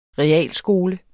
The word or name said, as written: realskole